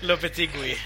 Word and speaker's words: Lopetegui: sen Lopetig Lopetegu, fan vad skulle man uttala det igen? Lopetegui eh, tog över, eller så han har ju varit petad sedan EM eh, e egentligen, Juan Fran.